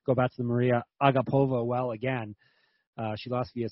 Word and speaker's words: Agapova: That's how you say it. Agapova.